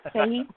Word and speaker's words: Fahey: Well, Fahey would be... – Okay. – The way most Americans say it. – Fahey? – Yeah, Fahey.